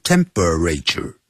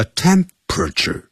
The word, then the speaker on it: temperature: 量脉搏用 feel 这个字 F-E-E-L feel one's pulse P-U-L-S-E take one's temperature 就这么说的，这个 temperature 不要念成四个音节，不要念成 temperature，叫 temperature，那个 p-e 那个 e 也是不发音的 temperature，念快 temperatures 啊，认为复数加了 s，同时呢抽血 and draw blood， technology that is 或是 that has already been used successfully by surgical robots。